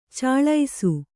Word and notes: ಛಳಿಸು: chaḷisu

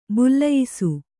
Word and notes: ಬಲಿಯಿಸು: baliyisu

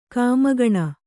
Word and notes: ಕಾಮಗಣ: kāmagaṇa

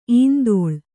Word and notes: ಇಂದೋಳ: indōḷa

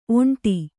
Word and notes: ಒಂಟಿ: oṇṭi

